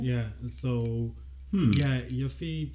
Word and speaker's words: Yuffie: And that makes you think of in Final Fantasy VII when you would recruit Yuffie? Yuffie? Yeah. How do you pronounce it? Yuffie? – Oh, man. Yif.